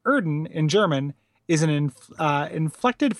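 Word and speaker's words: Oedon: Scott says, Erden is pronounced Erden because it's using the German umlaut O-E sound, usually represented by an O with an umlaut over it.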